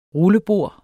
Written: rullebord

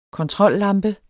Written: kontrollampe

